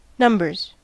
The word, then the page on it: numbers: American English